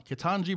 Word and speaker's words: Ketanji: Ketanji Brown Jackson.